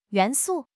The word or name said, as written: 元素